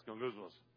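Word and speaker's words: gangousmos (γογγυσμός): Everybody gets to learn the word gangousmos, okay? Except for you got to say it like this, gangousmos.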